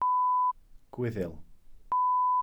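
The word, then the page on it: Gwyddyl: Welsh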